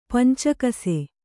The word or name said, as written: ಪಂಚಕಸೆ